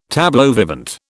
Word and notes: tableau vivant: British English

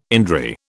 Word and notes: indri: British English

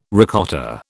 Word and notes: ricotta: British English

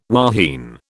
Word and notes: wahine: British English

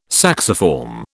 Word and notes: sacciform: British English

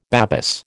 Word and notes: Babis: American English

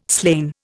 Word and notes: slain: Indian

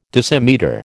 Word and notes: decimetre: American English

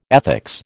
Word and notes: ethics: American English

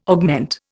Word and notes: augment: Indian

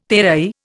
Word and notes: terai: Indian

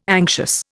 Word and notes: anxious: Indian

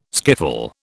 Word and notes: skiffle: British English

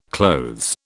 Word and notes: clothes: British English

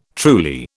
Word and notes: truly: British English

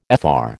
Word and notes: Fr: American English

